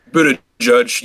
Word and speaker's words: Buttigieg: You mentioned it earlier, but it's Pete Buttigieg. – Buttigieg. You should have said the word Buddha and then judge. – Buddha and judge.